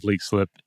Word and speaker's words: Leixlip: Now, specifically with Leek Slip here, this is located near the town of Leek Slip in northeast Ireland. It's spelled L-E-I-X-L-I-P.